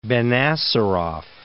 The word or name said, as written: Benacerraf